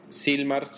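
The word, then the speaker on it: Sylmar: Silmar se escribe S-Y-L de Lulú, M de Mamá, A-R.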